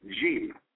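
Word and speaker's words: jim (جيم): All right, you go to the Arabic jim, J-E-E-M, all right, is how it's pronounced, jim, all right?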